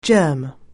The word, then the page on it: germ: anglais